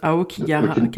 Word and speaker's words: Aokigahara: Aokigahara.